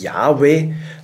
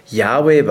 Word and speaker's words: Yahweh: Das Tetragramm Yud-Hei Wav-Hei im hebräischen J-H-W-H. Man hat den Gottesnamen als Yahweh ausgesprochen. Ihr seht hier eine Folie mit einem griechischen Text. Anhand von griechischen Texten konnte man das rekonstruieren, dass die Ur Aussprache ursprünglich Yahweh war.